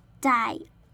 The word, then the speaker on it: dioxide: 先看前面的部分，前面部分呢就先发 car，那后面的 bon 的 o 发作嘴巴微张的的音，整个单字念作 carbon，而后面的 dioxide 的 di 的 i 呢，我们发 i 的音，后面再念 oxide，整个单字念作 dioxide。